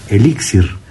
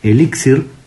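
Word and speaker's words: elíxir: Pero que en algunos países, sobre todo en América, se utiliza mucho la palabra elixir. Uh-huh. Y en ese caso, si hablamos de la palabra elixir, tiene que ir con acento en la primera I, porque es una palabra grave. – Claro. – Que debe de estar acentuada.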